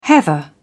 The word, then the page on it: heather: British English